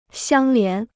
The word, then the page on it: 相连: xiāng lián